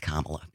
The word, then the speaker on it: Kamala: like it's pronounced Kamala.